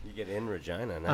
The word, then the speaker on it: Regina: Regina. – Regina.